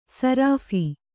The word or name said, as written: ಸರಾಫಿ